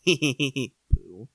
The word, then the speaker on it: Pooh: Is it Pooh or Poe? – It is spelled Pooh. – Pooh.